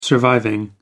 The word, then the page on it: surviving: American English